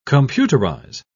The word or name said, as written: computerize